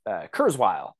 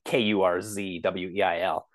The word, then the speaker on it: Kurzweil: Kurzweil, and I think that's how it's pronounced. It's like Kurzweil.